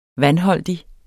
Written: vandholdig

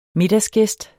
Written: middagsgæst

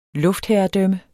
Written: luftherredømme

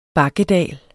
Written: bakkedal

